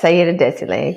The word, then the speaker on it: Saida Désilets: Yes, Saida Désilets, exactly.